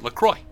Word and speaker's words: LaCroix: but if you go to the LaCroix website, they actually say you pronounce it LaCroix.